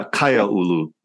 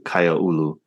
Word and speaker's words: Kaiaulu: Yeah, Kaya Ulu. Yeah, K-A-I-A-U-L-U, Kaya Ulu.